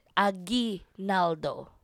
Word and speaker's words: Aguinaldo: If you pronounce G... How do you say this word? G-U-I-L-T-Y. – Aguilto. Aguilty. – What? Guilty. – Aguilty. – Why aguilty? I'm just asking you to pronounce guilty. That's a G-U-I, guilty. Right? Right? So yeah. That's how you pronounce it. Aguinaldo.